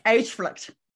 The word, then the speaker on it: Uitvlugt: It's Uitvlugt.